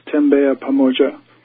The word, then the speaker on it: Tembea Pamoja: It's a Swahili term, and it's pronounced Tembea Pamoja.